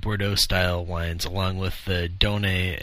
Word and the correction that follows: Doyenne: Anyhow, how do you say it? Donay? – Doyenne. – Doyenne. – D-O-Y-E-N-N-E. – D-O-Y-E-N-N-E, correct. – Doyenne.